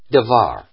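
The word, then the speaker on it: Devar (דבר): It's Dalet, Vet, Rosh. Devar is how you say it.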